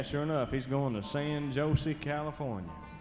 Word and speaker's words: San Jose: but I believe that's pronounced San Jose. The J is silent and pronounced like an H.